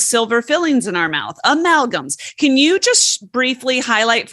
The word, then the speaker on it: amalgams: I can never say amalgams. It's fillings. – Perfect. Perfect. But you said it right. Amalgams is exactly right.